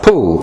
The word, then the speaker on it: Pole: But on the large white box were painted the words Reginald Poole, spelt P-O-L-E and pronounced Poole, we are told, who died in 1558 and who was the last Archbishop of Canterbury to be in communion with the See of Rome.